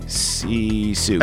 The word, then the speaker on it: Sisu: Or is it Sisu? I think it's Sisu. – Sisu. S-I-S-U. Yeah, no, no, you're right. My bad. So this is a 2002. So it's spelled S-I-S-U.